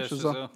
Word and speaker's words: Chazelle: Yeah, Chazelle.